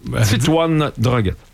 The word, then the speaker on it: Titouan Droguet: le français Titouan Droguet. – Qui? – Est-ce que j'ai bien prononcé? Titouan Droguet.